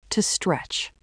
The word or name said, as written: to stretch